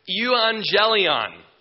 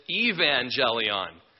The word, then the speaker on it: euangelion: It's from the Greek word euangelion, all right? And actually, we would pronounce it evangelion.